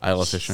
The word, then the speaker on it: Isla Fisher: And Isla Fisher. Am I saying her name right? – Isla Fisher.